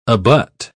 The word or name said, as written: abut